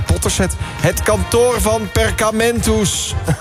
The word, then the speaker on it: Perkamentus: Het kantoor van Perkamentus.